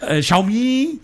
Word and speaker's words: Xiaomi: Ist mir scheißegal, so wird das jetzt ausgesprochen. Xiaomi heißt das, Alter. – Xiaomi? – Xiaomi.